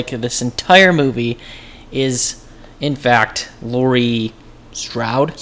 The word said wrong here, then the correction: Strode: Strode. – Strode. Jesus Christ. I get her name wrong all the time.